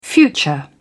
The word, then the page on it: future: British English